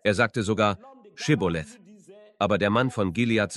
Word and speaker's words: Schibboleth: Dann sagten sie zu ihm, wenn du nicht Ephraim bist, sag Schibboleth. Aber er sagte Sibboleth, weil er nicht gut aussprechen konnte.